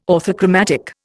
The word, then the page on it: orthochromatic: Indian